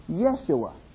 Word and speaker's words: Yeshua: As you know, there are no J's in Hebrew. So Jesus is not really the way the name was pronounced. All J's in Hebrew are Y's. So the way to probably pronounce Jesus' name as he would have recognized it is Yeshua.